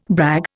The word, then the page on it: brag: Indian